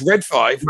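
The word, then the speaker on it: Red Five: I just I want them to do Red 5 so Meatball can stop giving us Ray 5. You're saying it wrong. – They'll give us sorry, they'll give us Red 5.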